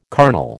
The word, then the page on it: carnal: American English